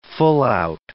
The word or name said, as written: full-out